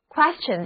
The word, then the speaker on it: question: ion 是名词后缀，所以呢，搜寻寻求问题的答案就是 question， question， question 的意思，就是问题、疑问或者难题。这里的字母 t 的读音是 ch。